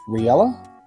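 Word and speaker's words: Reala: Remember the name is pronounced Riella? – So seeing as this is Jeopardy.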